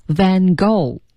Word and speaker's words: Van Gogh: 对对，而且那个 go 却是大写的，而且 go 它那个 gh 是不发音的，Van Gogh